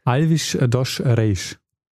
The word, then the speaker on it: Alves dos Reis: Also äh, Reisch. Äh, Reisch. Alvis Dosch Reisch.